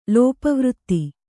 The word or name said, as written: ಲೋಪವೃತ್ತಿ